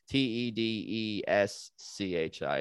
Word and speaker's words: Tedeschi: T E D E S C H I is how you spell Tedeschi. Italian pronunciation, Tedeschi.